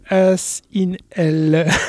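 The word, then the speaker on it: Asinhell: Il s'agit donc de As in Hell. Pardon, la façon dont j'ai prononcé peut paraître bizarre. – Non, As in pas, Hell. Voilà, c'est pas un cul en enfer.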